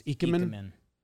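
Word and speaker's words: ikemen: It's a soft I. – It's Eichmann.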